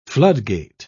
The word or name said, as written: floodgate